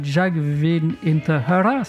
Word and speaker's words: Jag vill inte höra: Ça se prononce bien « Jag vill inte höra », c'est ça, c'est ce que vous aviez dit tout à l'heure, Jean.